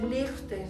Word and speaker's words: Liechtenstein: Bueno, no sé, porque yo sé que la Che en los alemanes la ponen G y EI le ponen AI. Entonces, Liechtenstein.